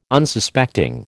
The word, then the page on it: Unsuspecting: American English